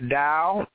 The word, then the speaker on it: Tao: The Tao, T-A-O, of T as in Tom, A-O. It looks like Tao, but it's pronounced D-O-W, but it's spelled T-A-O.